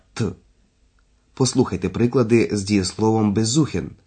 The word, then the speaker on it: besuchen: На ці префікси не падає наголос. До таких префіксів належать «be» або «er». – «Besuchen», «erzählen».